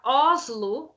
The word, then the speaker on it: Oslo: Pois é, nessa canção fica muito claro a diferença, fica muito clara a diferença do O aberto, por exemplo, na palavra Oslo, essa é a pronúncia que ele faz ao ser baiano.